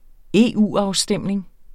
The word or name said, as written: EU-afstemning